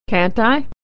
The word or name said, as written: can't